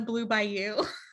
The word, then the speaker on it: Bayou: Wait, since this is Patty's Disney download, shouldn't it be the Blue Bayou? – Really? – I said it correctly.